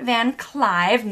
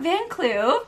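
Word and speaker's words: Van Kluyve: So Rachel and Richard Van Clive, not Van Clue, which is awesome because their last name is spelled K-L-U-Y-V-E, but it's pronounced Clive.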